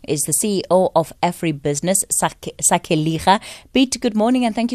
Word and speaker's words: Sakeliga: Yes, our name is Sakeliga, but you did very well. – Okay, Sakeliga.